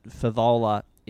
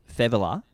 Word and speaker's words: Fevola: I'm pretty certain that Favola is Fevola.